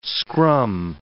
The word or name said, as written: scrum